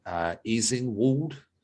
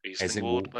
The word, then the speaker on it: Easingwold: As part of their research, the two spent a week at the Home Office Training Center for Official Survivors in Easingwold. As in Wald. It is spelled E-A-S-I-N-G-W-O-L-D. – Eastingwald, maybe. Eastingwald.